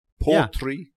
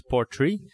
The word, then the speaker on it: Portree: Portree. – Yeah, you pronounce it as Portree.